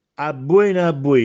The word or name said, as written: Abbuène, abbuène